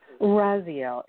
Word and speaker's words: Raziel: Is that, you said Raziel or Raphael? – Raziel. It's spelled R-A-Z-I-E-L. – I-E-L.